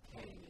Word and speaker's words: Kean: K-E-A-N. Kane is the way it's pronounced.